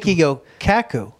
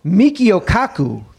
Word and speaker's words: Michio Kaku: Michio Kaku. – Michio Kaku. Thank you. – Jesus. – Does he play for the Cowboys? Then I'm sorry. Michio Kaku.